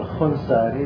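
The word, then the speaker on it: خوانساري: ولذلك مثلا خنسار طبعا يكتبونها الخنساري مثلا خاء واو ألف، خوان ساري كان خوان ساري هي خان ساري، لكن تلفظ خنساري باعتبار أن في اللغة الفارسية في اللهجة العامية يبدلون الألف واو، يقولون خيابان خيابون مثلا، صحيح؟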